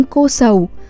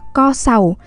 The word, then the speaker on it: Co Sầu: đó là địa danh cô sầu thật ra trong tiếng Tày thì phải đọc là co sầu, và có nghĩa là góp cơm cho bữa chiều.